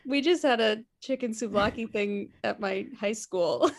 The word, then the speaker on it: souvlaki: Rice and beans, curry chicken, ratatouille, steak, seared tuna, or souvlaki? Souvlaki? Am I saying that right? – Yeah. – S-O-U-V-L-A-K-I.